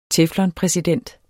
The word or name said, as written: teflon